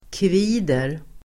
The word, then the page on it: kvider: [kv'i:der]